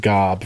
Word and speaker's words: GOB: He's got Michael and Job, it looks like it's pronounced Gob for those of you who don't watch the show.